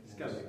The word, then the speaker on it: Skellig: Hoe moet dat goed uitspreken? – Skellig. – Skellig. Yes.